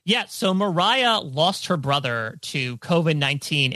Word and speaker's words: Mariah: So her name is pronounced Mariah, like Carrie, Sharon.